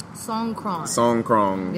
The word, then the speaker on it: Songkran: Am I saying that right? – Songkran. – Yes.